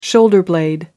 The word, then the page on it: shoulder blade: American English